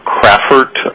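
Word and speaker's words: Crafford: Yes, sir, my name is Tom Crawford. Last name is spelled C-R-A-F-F, as in Fred, O-R-D, and I'm the director of the Office of Project Management and Permitting.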